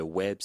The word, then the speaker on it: Ouèbe: Le web s'écrit O-U-E-B-E.